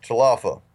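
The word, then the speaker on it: Tal Afar: We're in a little town called Talafa, or Talafa, it looks like it's spelled, or it's said Talafar, but it's pronounced Talafa, and it's right on the corner of Syria, Turkey, and Iraq, and it was flying from Iraq into Syria.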